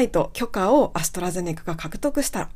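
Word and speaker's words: AstraZeneca: はい、ということで、アストラゼネカの発音は、アストラゼネカという風になります。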